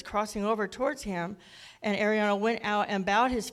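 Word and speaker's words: Araunah: floor of Arun, I can't never say his name. It's called Arauna, the Jebusite.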